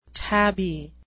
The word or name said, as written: tabby